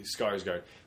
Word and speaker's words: Skarsgård: Is that how you say his name? – Yeah, Skarsgård.